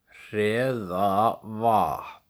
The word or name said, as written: Hreðavatn